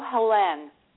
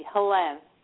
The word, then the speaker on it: Helenne: It was Helene, and it is now Helene. – Okay. Okay. – H-E-L-E-N-N-E. Helene. – Helene. Okay. – Tomas is Tomas. T-O-M-A-S.